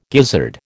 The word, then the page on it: gizzard: American English